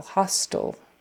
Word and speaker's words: hostile: H-O-S-T-I-L-E. Meaning not friendly. It, you say it the same way as H-O-S-T-E-L, hostile. So that T-I-L-E, for some reason, even though there's an E on the end, we're not making that I a long I. It's just hostile, It's one of those funny English vowel sounds at the end that isn't, it's kind of a, kind of a lazy pronunciation that has become the norm. So, hostile, hostile. Hostile forces were attacking the village. Hostile. Yawn, Y-A-W-N, like yawn.